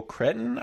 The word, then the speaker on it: Cretton: So this is directed by Destin Daniel Cretton, I think is how you say his last name, and Cretton.